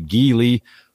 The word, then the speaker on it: Geely: That's G-E-E-L-Y. We often say Geely, But as near as I can tell, the proper pronunciation is Geely.